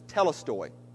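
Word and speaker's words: tetelestai: It's interesting because the Greek word for finished is, the word's pronounced, I don't know if I can even pronounce it, but it's telestoi.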